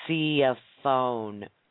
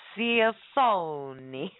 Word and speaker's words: Cioffone: I couldn't just, Jenny was helping me by putting the correct pronunciation, which is Cioffone, Cioffone. But to me, he's like, Cioffione.